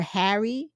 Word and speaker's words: Pharry: To the point where the main character in one scene says, my name is Taylor Harry, but the P is silent because his last name is spelled P-H-A-R-R-Y.